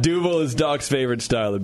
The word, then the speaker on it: Duvel: Well, he's saying Dubel, and you're saying Duvel. – Oh, Duvel. – Yeah. – Duvel or Dubel? – Yeah. – Dubel. You say Duvel, I say Dubel. – Spell it. Spell it. – D-U-B-B-E-L. – Okay, that's where you got the difference.